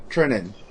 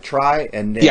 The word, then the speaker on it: Trinan: It's Trinan, right? Trinan. Trinan. Got it. I was just pronouncing it the way it was. It was like try and name. Yeah. So I went try and name.